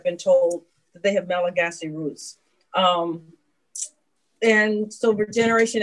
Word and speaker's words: Malagasy: Thank you for pronouncing it Malagasy. Every Malagasy person who's listening to this will just, they will beam because that's the perfect way to say it.